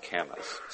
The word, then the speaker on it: camas: I believe the word is pronounced camas, C-A-M-M-A-S.